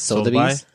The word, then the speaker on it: Sotheby's: Sotheby's, is that how you say it? – Sotheby's.